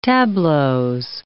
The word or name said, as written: tableaux